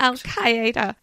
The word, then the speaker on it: Al-Qaeda: Was basically four terrorist attacks against the USA by the Islamic terrorist group Al-Qaeda. Am I saying that right, Sher? – Yes, I think you've got that correct. – Al-Qaeda.